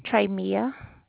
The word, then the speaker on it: Treymia: Trey Mia. – And how would you spell that? – T-R-E-Y-M-I-A.